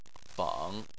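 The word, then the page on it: feng: tone 3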